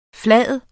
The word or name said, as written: flade